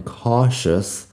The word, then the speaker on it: cautious: Fíjate en la pronunciación cautious. Se escribe con la T en medio. C-A-U-T-I-O-U-S es la forma de escribirlo. Cautious. Pero la T a veces en inglés Suena como si fuera SH, shh, cautious.